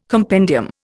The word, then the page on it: compendium: Indian